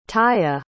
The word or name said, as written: taia